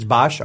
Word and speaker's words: Boetsch: We had, probably going to mispronounce this, Boitsch versus Henderson. B-O-E-T-S-C-H. – Isn't it Bosch? – Yeah, Bosch? It's Bosch.